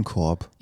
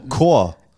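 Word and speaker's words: Corps: Was ist denn Korb? – Ja, diese Trupps, die so Ach so, Chor. – Chor, ja. Sorry. Aber schreibt man Korb.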